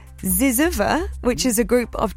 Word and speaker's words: zyzzyva: It's spelled Z-Y-Z-Z-Y-V-A. Ziziva.